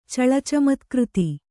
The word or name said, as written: ಚಳಚಮತ್ಕøತಿ